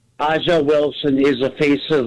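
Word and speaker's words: A'ja: Yeah, it's not Angel Wilson. It's Aja.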